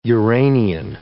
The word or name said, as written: Uranian